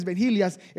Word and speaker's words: vigilias: Especially those Vigilias, that's how you say it in Spanish, Vigilias.